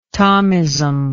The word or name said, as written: tomism